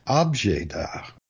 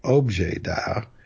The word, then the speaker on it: objet d'art: O-B-J-E-T-D apostrophe A-R-T. objet d'art, although in French it's objet d'art, which is more like English object. But people hypercorrect, wanting to sound more French, they'll say objet d'art, which is okay.